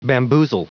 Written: bamboozle

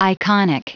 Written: iconic

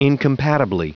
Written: incompatibly

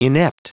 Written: inept

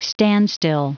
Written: standstill

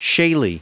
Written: shaley

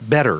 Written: bettor

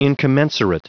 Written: incommensurate

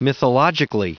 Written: mythologically

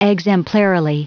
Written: exemplarily

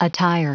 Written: attire

